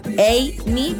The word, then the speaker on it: Amy: Inicia con A, se pronuncia Amy, pero inicia con A.